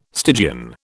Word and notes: Stygian: British English

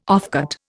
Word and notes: offcut: Indian

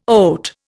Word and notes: oat: Indian

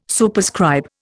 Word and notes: Superscribe: Indian

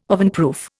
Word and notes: ovenproof: Indian

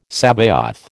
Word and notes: Sabaoth: American English

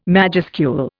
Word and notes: majuscule: Indian

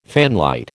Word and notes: fanlight: American English

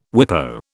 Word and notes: WIPO: British English

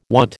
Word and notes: want: American English

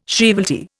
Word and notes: shrievalty: Indian

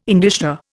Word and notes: indicia: Indian

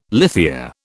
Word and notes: lithia: British English

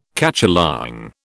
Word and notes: cacholong: British English

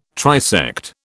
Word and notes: trisect: British English

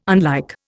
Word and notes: unlike: Indian

